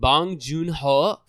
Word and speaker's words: Bong Joon-ho: Sam Mendes for 1917, and Bong Joon-ho for Parasite.